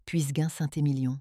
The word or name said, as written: Full-bodied